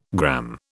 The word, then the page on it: gramme: British English